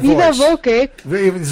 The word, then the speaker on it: viva voce: How do you say it? You say voce. – Yeah, so by voice. – Viva voce. – So viva voce. Voce, voca. Viva. It's by voice.